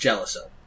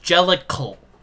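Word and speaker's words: Jellicle: So it's really not pronounced jealousy. – Nope, it's jellical. Like a cloud. – Evangelical? – Yeah, like evangelical. But drop the evan and just make it jellical about cats.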